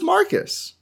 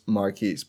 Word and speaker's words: Marques: So Marquise Bolden. – I thought it was Marcus. – No. – Is that you hearing from that from the broadcast or from outside sources? – From the pronunciation guide.